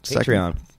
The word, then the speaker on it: Patreon: Hit up their Patreon. Is that how you say it? – Yeah. You got it, buddy. – All right. – Patreon.